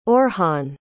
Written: orhan

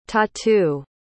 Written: tatu

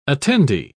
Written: attendee

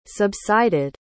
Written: subsided

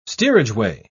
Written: steerageway

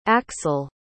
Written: aksel

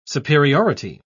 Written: superiority

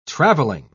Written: traveling

